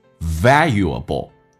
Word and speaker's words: valuable: 体验一下 most valuable, 英式发音叫 valuable, 美式发音叫 vowel, 这个先发 valuable.